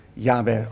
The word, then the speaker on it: Yahweh: Alleen hier staat het woord Yahweh, althans zo moeten we het uitspreken, J-W-H-W.